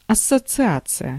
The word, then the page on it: förening: /fœrˈeːnɪŋ/